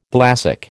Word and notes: thalassic: American English

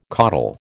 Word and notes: coddle: American English

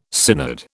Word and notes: synod: British English

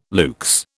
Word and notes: luxe: British English

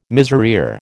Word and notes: miserere: American English

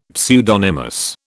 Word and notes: pseudonymous: British English

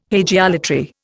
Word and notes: hagiolatry: Indian

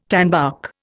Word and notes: tanbark: Indian

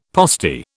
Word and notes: postie: British English

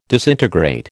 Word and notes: disintegrate: American English

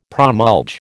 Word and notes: promulge: American English

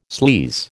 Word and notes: sleaze: American English

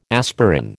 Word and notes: aspirin: American English

